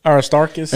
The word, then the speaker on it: Aristarchus: Aristocarchus. How do you say it? – Aristarchus.